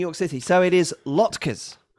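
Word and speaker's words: latkes: The proper pronunciation is as if you were saying the word lot followed by ker. Rory had pronounced it as lat.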